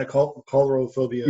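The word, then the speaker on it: Coulrophobia: Chorophobia. Is that how you pronounce that? The fear of clowns, isn't it? – Yeah, Chorophobia.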